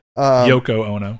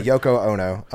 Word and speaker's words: Yoko Ono: Oko Yono. – I don't know why I can't ever say her name. – Yoko Ono. – Yeah, Yoko Ono.